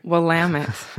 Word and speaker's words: Willamette: Willamette. yes. In Oregon, they say rhymes with damn it.